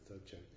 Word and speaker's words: Dzogchen: That's how you pronounce it, Dzogchen.